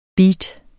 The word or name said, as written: beat